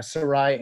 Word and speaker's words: Sarai: Is that how you'd pronounce it? Yeah, Sarai.